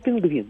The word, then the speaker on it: пингвин: Вот сейчас мы говорим пингвин, да? Да. И э, ударение у нас на последний слог.